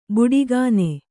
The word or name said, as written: ಬುಡಿಗಾನೆ